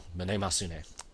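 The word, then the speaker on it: Mnemosyne: That is the correct Greek pronunciation, though. Manemosune.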